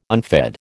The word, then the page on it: unfed: American English